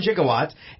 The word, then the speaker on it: gigawatts: You need to be hit by lightning to trigger the 1.21 gigawatts, and it sends him back to 1885. – Side note... Gigawatts is not a mispronunciation like a lot of people think it is. It's just an older pronunciation.